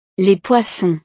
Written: les poissons

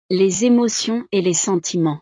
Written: les emotions et sentiments

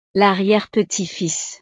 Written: l'arriere-petite-fils

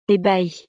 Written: ebahi

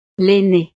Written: l'aine

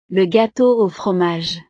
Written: le gateau au fromage

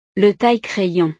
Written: le taille crayon

